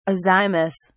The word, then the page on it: azymous: British English